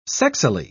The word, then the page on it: sexily: British English